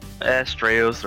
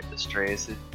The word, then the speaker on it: Astraeos: Astraeus or Astraeus, it's fine.